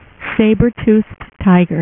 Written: sabretooth

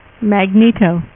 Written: Magneto

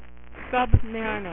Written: namor

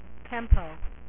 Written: Tempo

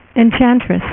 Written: enchantress

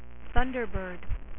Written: Thunderbird

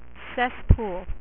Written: Cesspool

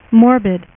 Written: Morbius

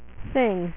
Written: Thing

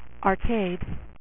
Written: arcade

